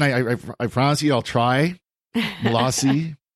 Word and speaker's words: Malzahn: Susan Gardner 19 on Twitter says, with all respect, please learn that Joel Malzahn's name is pronounced Malazi.